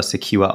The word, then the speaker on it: Secjur: Und geht's bei Secure, das schreibt man übrigens S-E-C-J-U-R, e ähm, wird, ihr sprecht es aber Secure aus, wenn ich, ähm, nicht, nicht Secure oder so, oh.